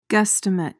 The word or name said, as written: guesstimate